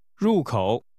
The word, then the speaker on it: 入口: 入口 is pronounced with a falling and a falling rising tone.